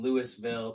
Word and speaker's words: Louisville: After I moved down south, I noticed they, in the south, they don't pronounce Ville. On anything. It's vol. Mm. So like Louisville. Where up north, Louisville. – Yes. – Down south, Louisville. Bad.